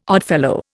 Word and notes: Oddfellow: Indian

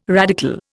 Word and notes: radical: Indian

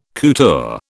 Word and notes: couture: British English

